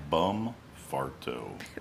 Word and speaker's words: Bumfarto: Bumfardo. – Bumfarto. And it is spelt that way.